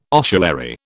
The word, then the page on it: ossuary: British English